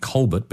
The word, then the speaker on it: Colbert: Steve Colbert, looks like Steve Colbert, but you pronounce it Colbert, I think, don't you?